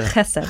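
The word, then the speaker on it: chesed (חסד): So if you've been pronouncing this as chesed for a long time, totally. You can now change to chesed. – Chesed, yeah. – Chesed. Not clear chesed. The throat. – Chesed.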